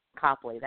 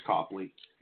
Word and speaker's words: Copley: Copley, that's how you say it. I always call him Coakley. That's what they called him in Wilkes-Barre Scranton, so that's where I learned to say his name. – Nope, it's Copley.